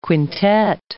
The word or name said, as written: quintet